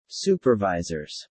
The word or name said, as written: supervisors